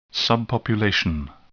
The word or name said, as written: subpopulation